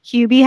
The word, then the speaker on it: Hubie: Is that how you pronounce it? – Hubie Halloween. – Okay, yep. I thought it was Hubby Halloween, but yeah. okay, I was wrong.